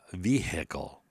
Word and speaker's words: vehicle: So vehicle. I've heard people in court say the vehicle. It's a H is silent, you fucking retard.